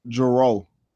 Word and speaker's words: Jerreau: Did you say Jerreau? Jerreau. How do you even spell that? J E R R E A U.